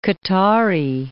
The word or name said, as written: Qatari